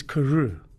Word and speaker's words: Carew: my surname is Carew. Yeah. It's spelled C A R E W.